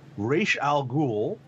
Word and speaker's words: Ra's al Ghul: During his time on Batman, he created / co-created Ra's al Ghul, which is how it was to be pronounced, Talia al Ghul, Leslie Tompkins, Azrael, and Richard Dragon.